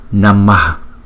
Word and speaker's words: Namah: Cái chữ H có dấu nặng ở dưới, chúng ta đọc là Namah, Namah, Namah, Namah.